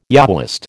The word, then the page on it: Yahwist: American English